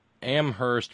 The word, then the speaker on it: Amherst: Signed, Terry in Amherst, where only the H is silent.